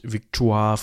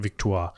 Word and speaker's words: Victoire: So, und das war eben Edward Augustus, war Vater von Victoria und er hat seine Tochter Victoria zusammen gehabt mit Victoire von Sachsen-Coburg-Saalfeld. So würde ich sie jetzt mal aussprechen. Ich würde das jetzt mal französisch aussprechen. – Äh, kann auch Victorie sein.